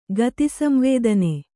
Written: ಗತಿಸಂವೇದನೆ

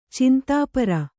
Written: ಚಿಂತಾಪರ

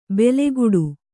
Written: ಬೆಲೆಗುಡು

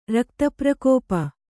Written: ರಕ್ತಪ್ರಕೋಪ

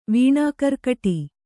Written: ವೀಣಾಕರ್ಕಟಿ

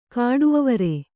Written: ಕಾಡುಅವರೆ

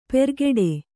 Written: ಪೆರ್ಗೆಡೆ

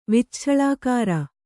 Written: ವಿಚ್ಛಳಾಕಾರ

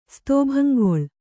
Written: ಸ್ತೋಭಂಗೊಳ್

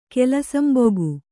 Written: ಕೆಲಸಂಬೊಗು

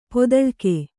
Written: ಪದುಳಿಕೆ

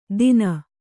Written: ದಿನ

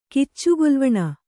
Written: ಕಿಚ್ಚುಗೊಲ್ವಣ

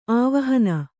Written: ಆವಾಹನೆ

